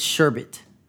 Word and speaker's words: sherbet: It's sherbet. That's how the word is pronounced. – Yeah, it's sherbet. – It's not sherbet. – It's sherbet. – There's no R.